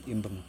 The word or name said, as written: imbuN